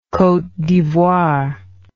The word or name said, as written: cote d'ivoire